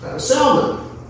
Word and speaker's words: Salmon: But then you go on a couple generations and you find Salmon. I know you pronounce that salmon if we were talking about fish, but we're not.